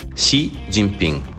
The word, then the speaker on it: Xi Jinping: Xi Jinping. – Ok? Quindi la X si pronuncia sh, sh, sh. E la G quasi non si pronuncia.